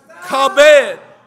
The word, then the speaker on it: kabed: This word honorable in the Hebrew is pronounced kabed, and it can mean either numerous, rich, or honorable.